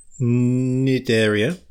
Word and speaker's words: Cnidaria: After that, um, it's a, it's a genus of a small freshwater organism of the Phylum Sindaria, a class of hydrozoa? Phylum Cnidaria. Is it the C silent? The C is silent.